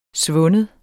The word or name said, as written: svind